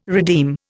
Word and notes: redeem: Indian

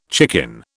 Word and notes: chicken: British English

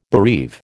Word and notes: bereave: American English

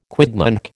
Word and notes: quidnunc: American English

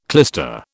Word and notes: clyster: British English

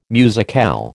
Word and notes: musicale: American English

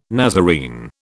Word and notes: Nazarene: British English

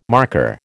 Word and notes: marker: American English

